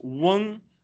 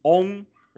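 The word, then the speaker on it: Wen (翁): But in standard Mandarin, it's Wen is W E N G. But in Chongqing or in Sichuanese, it's Ong.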